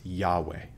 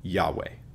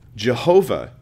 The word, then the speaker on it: Yahweh: It's often translated, most often nowadays people would say it's probably pronounced Yahweh. Yahweh. Jehovah was a Latinization or a Germanization of that word, meaning pulling it into other languages and replacing certain sounds with other sounds, as happens when you move languages with words. So the pronunciation is incorrect.